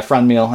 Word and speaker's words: Franmil: Franmil? – Yeah, Franmil, I think, yep.